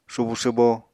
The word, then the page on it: subu swbo: Apatani